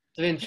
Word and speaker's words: Sevinj: This is, I hope, I'm so sorry if I pronounced your name correctly, Sevinj. – Sevinj, it's correct. – Sevinj, who is currently based in Azerbaijan.